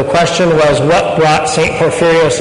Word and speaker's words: Porphyrios: Ready? No. Porphyrios. Now, we can, we can all get this. Ready? Porphyrios is four syllables. I won't have you say Kovsakalivia. Just Porphyrios.